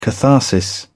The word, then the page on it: catharsis: British English